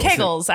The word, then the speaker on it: Kegels: Kegels, actually, is what's how you pronounce it. – What did I say? – You said kankles.